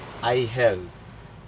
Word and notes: aihel: Aneityum

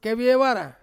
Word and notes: keviewara: Ansus

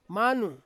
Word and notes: manu: Ansus

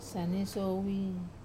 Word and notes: sane-soi: Wamesa

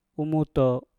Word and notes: umu to: mpur

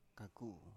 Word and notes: kaku: Biak

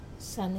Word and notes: sane: Wamesa

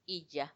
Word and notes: iyya: Macuiltianguis

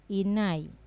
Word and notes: nai: Wamesa

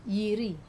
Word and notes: iri: Wamesa

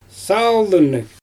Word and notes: saaldynnyG: Xyzyl

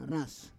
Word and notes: ras: Roon

